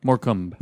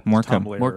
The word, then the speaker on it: Morecambe: And today we're talking about Moonlight Bay off of it's from 1963, Morecambe and Wise show, but it was Morecambe? – Silent E. – Yeah. – Abercrombie? – Morecambe. – Morecambe.